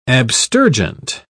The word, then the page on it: abstergent: British English